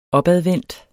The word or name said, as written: opadvendt